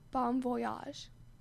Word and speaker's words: Bon Voyage: Bon Voyage.